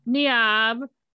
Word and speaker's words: Niamh: Her name is Niamh, which I know I'm pronouncing that kind of wrong. It's spelled N-I-A-M-H, and it's often these days spelled Neve. But it's kind of pronounced a little different than Neve.